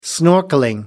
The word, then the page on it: snorkelling: American English